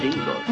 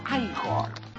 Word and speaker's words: Igor: Frankenstein, usted debe de ser Igor. – No, se pronuncia Igor.